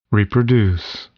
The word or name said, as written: reproduce